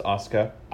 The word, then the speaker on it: Asuka: I'm trying to say her name the way you said to pronounce it too, right? Asuka, right? Asuka. So, do it for me again. – Yeah, her name's Asuka.